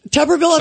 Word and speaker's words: Tuberville: Tuberville of, Air, of Alabama.